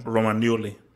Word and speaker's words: Romagnoli: And obviously this is maybe something for the complicated Chelsea show a little bit more, but they benched Romagnoli, if I'm pronouncing it. Romagnoli.